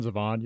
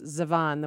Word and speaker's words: Zevon: Warren Zevon, you get to hear that all year round anyway. – Yeah, I don't know that anyone pronounces Zevon the way you do. I think it's Warren Zevon, but Zevon.